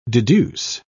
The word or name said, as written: deduce